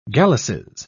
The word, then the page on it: galluses: British English